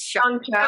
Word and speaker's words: Shang-Chi: It's Shang-Chi.